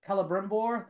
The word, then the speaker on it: Celebrimbor: So showing this much of Sauron and showing how he's manipulating Celebrimbor, thank you, I said that right.